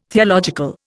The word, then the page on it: theological: Indian